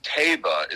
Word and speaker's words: Taiba: So Messier and Taiba is how you pronounce it, T-A-I-B-A.